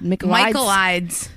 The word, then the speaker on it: Michaelides: Alex McGlades. – Yes. – Wait. McGlades. McGlades is not it. McGlades. – Michaelides. – That's the one. Michaelides. – Michael. You think it's Michaelides? – I don't know. – Michaelides? – Michael Eads.